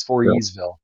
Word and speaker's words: Voorheesville: I think you don't pronounce the H. It's Voorheesville.